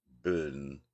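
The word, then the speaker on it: Bön: I'm not sure if you've looked into the so-called Bun tradition, B-O-N. Some people pronounce it Bon, but I guess it's really Bun.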